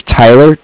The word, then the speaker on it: Tyler: My name is Tyler, T-Y-L-E-R, and I'm calling with Cardiff. It's spelled C-A-R-D-I-F-F, as in Frank Frank.